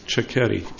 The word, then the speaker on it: Chiquetti: And over the last few months, I've heard many pronunciations of this, but I've been assured by the locals here that it is Chiquetti, and it's been helpful to remember if you move the H out of the middle and put it at the beginning, it seems to work better that way.